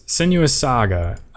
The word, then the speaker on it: Senua's Saga: Senua's Saga.